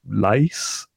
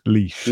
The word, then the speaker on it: Laois: Not for his name, it's Ronan, but it's from County Leash. – L-A-O-I-S. – Leash. – Leash. – Why is that Leash? Come on. Nothing like Leash.